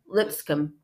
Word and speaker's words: Lipscomb: Is that how you say your last name? Is that proper? Lipscomb. Mm-hmm. Okay, just shorten it up.